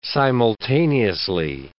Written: simultaneously

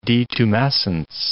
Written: detumescence

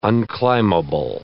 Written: unclimbable

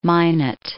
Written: Minot

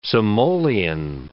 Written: simoleon

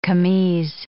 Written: camise

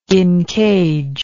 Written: incage

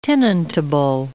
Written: tenantable

